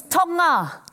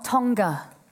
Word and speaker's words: Tonga: It's Tonga, not Tonga. The G is silent in Polynesian languages.